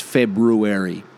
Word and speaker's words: February: But I only just realised that February has another R in it right after the B. Like, it's February. And some people say it like that too.